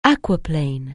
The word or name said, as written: aquaplane